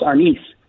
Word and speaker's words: Arnis: Brazilian Jiu-Jitsu, mixed martial arts, Bruce Lee's Jeet Kune Do, and Filipino martial arts known as Kali, Eskrima, and Arnis. Did I say that correctly? – You're pretty close. Arnis.